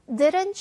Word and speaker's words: didn't: 일단은 t 살려보겠습니다. 너무 살리는데 집중은 하지 말고 바람 소리만 내면 됩니다. 그녀가 하지 않았니입니다. Didn't she? 자, 근데 많은 경우 원어민들 그냥 didn't 하고 묵음하고 she 합니다.